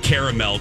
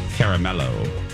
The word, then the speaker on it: caramel: And everybody knows that caramel came from the 18th century Spanish turned French word caramello. Caramello. So you should pronounce it and be respectful. Caramel. Caramel.